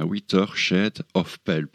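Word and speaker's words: A Whiter Shade of Pale: Et euh, en 1967, eh bien, sortait le tube du groupe Procolarum, hein, vous vous en souvenez de ce tube de 8 a shell of Pell, Est-ce que je prononce bien Witter shed of Pell, plutôt, voilà.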